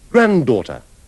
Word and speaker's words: granddaughter: Invece nella pronuncia inglese di nonno, nonna e nipote, maschile e femminile, scompare la lettera D, o ne resta solo una, come noterete nell'ultima parola. – Grandfather. Grandmother. Grandson. Granddaughter.